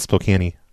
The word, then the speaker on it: Spokane: Yep, Spokane. It's pronounced Spokane.